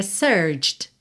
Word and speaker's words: searched: No, porque a gente não pronuncia esse E, e aí seria searched. Só que esse último som dessa letra D já vai juntar no T de to, ou seja, você vai pronunciar como se você tivesse search no presente, e depois to, search to find, ok?